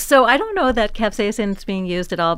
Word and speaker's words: capsaicin: So you mentioned how certain foods like capsaicin, how do you pronounce it? – Capsaicin.